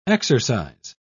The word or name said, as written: exercise